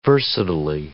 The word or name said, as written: versatilely